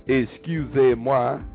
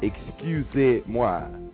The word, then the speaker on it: excusez-moi: And it's pronounced excusez-moi, E-X-C-U-S-E-Z, with a hyphen, M-O-I, excusez-moi.